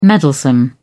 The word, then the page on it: meddlesome: British English